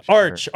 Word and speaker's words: Arch: I think it's it rhymes with March. – Arch.